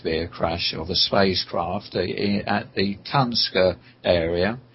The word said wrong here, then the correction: Tunguska: Tunguska? In Russia? Siberia? – Yes. Right, yes, that's the one.